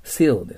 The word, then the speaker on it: seal: Please put your seal って言っちゃダメです。シールです。切手のシール。日本語で言うと。でも、カタカナのシじゃないんですね。